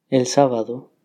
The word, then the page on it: el sabado: Spanish